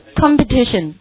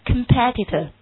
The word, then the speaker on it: competitor: Yes， competition。好，那今天我们来再学一个词 competitor，它的读音变了，前面的几个读音都变了，上面读 competition，对不对？下面一个读 competitor，以 OR 结尾， OR 结尾一般我们都说是 ER 结尾， OR 结尾习惯上是指什么的多？